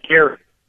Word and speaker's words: Garrard: Now he is a prosecutor at, is it Garrard or Girard? Soft G or hard G County? – It's Garrard, actually.